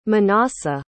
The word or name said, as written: Menassah